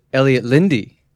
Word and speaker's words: Elliot Lynde: Today we are honored by our second guest, Elliot Lynde.